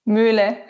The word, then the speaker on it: Muehle: Muehle.